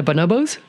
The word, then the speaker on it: bonobos: but am I pronouncing this right, the bonobos? Why and how is it that we're much more like the bonobos? – Yeah, that's right.